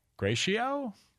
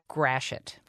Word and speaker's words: Gratiot: It's spelled G-R-A-T-I-O-T and it is pronounced... – Gratiot? – Oh. – Gratiot. – Gratiot. – Gratiot. Okay. Yes, it is the undoing of many an announcer when they come in from outstate.